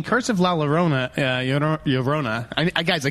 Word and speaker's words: La Llorona: You say it like an American. La Llorona.